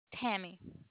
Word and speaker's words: Tammy: Tammy. – Spell that for me. – T-A-M-M-Y.